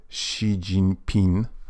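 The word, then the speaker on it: Xi Jinping (习近平): Xi Jinping. A? Tak, sprawdziłem przed chwilą wymowę, więc to może będzie bliżej.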